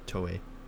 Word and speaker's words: Toei: So apparently everyone's saying, because when that happened, people were tweeting Toei, and did I pronounce that Toei? – Yeah, Toei.